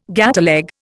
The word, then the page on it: gateleg: Indian